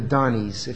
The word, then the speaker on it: Dani: One example was the story of the Donnies. If you're not familiar with that term, you should be. Any of you who have been around Emmanuel Faith very long are. It's spelled D-A-N-I, Donnie.